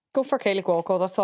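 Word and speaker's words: Kaley Cuoco: It's Kaylee Cuoco, is that how you say it? – Mm-hmm.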